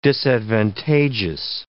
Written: disadvantageous

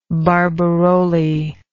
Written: barbirolli

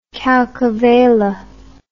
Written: calcavella

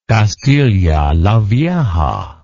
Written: Castilla la Vieja

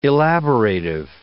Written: elaborative

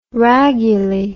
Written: raguly

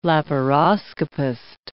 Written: laparoscopist

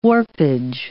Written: warpage

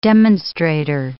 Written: demonstrator

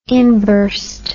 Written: inburst

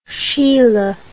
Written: Schiele

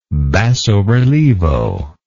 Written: basso relievo

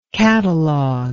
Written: catalogistic

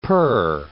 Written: per

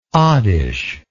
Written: oddish

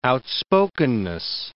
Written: outspokenness

